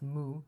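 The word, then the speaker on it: mu (無): In Korean and Japanese, it's pronounced mu, so I'm going to say he replied mu.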